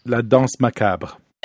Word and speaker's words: La Danse Macabre: La Danse Macabre.